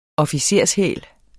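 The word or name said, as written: OFFICERSHÆL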